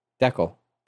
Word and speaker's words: decal: Okay, so it's spelled D-E-C-A-L. – D-E-C-A-L? – Yeah. – Deckel.